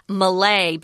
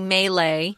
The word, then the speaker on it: Malay: Now, when I read this, Tom, I pronounce Malay, but you say Malay. They're both correct. One of my friends is from Malaysia, and I've heard Malay, but Malay is absolutely correct. They're both correct pronunciations. You can use whatever you want.